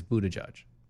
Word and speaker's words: Buttigieg: His actual pronunciation is Buttigieg. – Okay, how in the hell do people get Buttigieg out of the spelling of his name? – How do you get judge out of G-I-E-G? – Yeah, uh-huh.